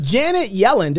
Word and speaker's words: Janet Yellen: Janet Yellen.